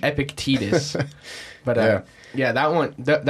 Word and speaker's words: Epictetus: Like I listened to the one by Epictetus. – Yeah. – the Discourses of Epictetus or something. – I don't know that one. – It's not Epictetus. It's Epictetus.